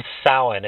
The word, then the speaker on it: Samhain: nothing annoys a pagan more than when people say, hey, what are you doing for Samhain? It's Samhain, and it's spelled S-A-M-H-A-I-N.